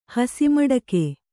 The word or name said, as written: ಹಸಿಮಡಕೆ